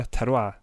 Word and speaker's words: terroir: Och de tar mycket inspiration från, och här kommer det med vinet, från eh, ett vinterroir, hur säger man, uttalar man det? Terroir, det är lite landskapet och sånt, det är klimatet och terrängen då som liksom bidrar till smaken och sånt.